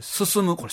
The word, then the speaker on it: 小清水進: いうことで小清水進む、これ進むって読むんですね。